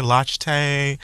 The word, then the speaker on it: Lochte: I wasn't sure if it was like Lochte, Monster, Lochte, Latte. I said locked. – I'm pretty inside. – Sure it's Lochte.